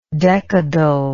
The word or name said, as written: decadally